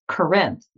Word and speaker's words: Corinth: My name isn't Corey legally. It's Corinth, like Corinthians.